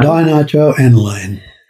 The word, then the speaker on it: Dinitroaniline: Dinitroaniline.